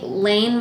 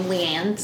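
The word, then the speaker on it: Liane: I met and was very excited to meet Lane Moriarty. You don't pronounce her name Leanne, so this was news to me.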